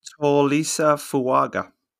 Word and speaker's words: Tolisa Fuaga: Just to correct, Binner's Oregon State kindly gave us the phonetic pronunciation. It's Tolisa Fuaga.